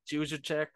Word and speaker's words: Juszczyk: Like, Juszczyk.